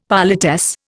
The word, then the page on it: politesse: Indian